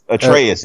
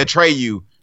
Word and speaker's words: Atreus: Atreus. – Yeah, Atreus. Yeah. – I keep wanting to say Atreyu.